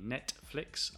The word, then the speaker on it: Netflix: Yes, notice I don't pronounce the T when I'm speaking naturally. Netflix, I say Netflix, which a lot of people do.